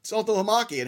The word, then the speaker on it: Saltalamacchia: So I'm one of the few people that when I saw that name, I went, oh, Saltalamacchia. And everybody's like, how do you say that name? I was like, I've been saying it since I was 5.